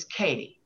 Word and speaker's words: Katie: And my new name is Katie. And it's spelled K-A-T-I-E.